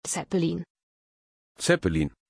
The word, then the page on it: Zeppelin: German